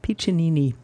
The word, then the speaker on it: Piccinini: Oh, there's an extra there's an extra syllable in there, Piccinini.